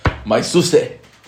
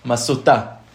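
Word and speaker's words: מסותא: Okay, famous case, maisuse. Yeah, that's how you say it on the Olam HaYeshivot. So we'll say it like this, masuta.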